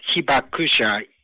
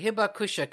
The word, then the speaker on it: Hibakusha: if I'm pronouncing correctly, is Hibakusha? – Yes, Hibakusha, yes. – Okay, Hibakusha.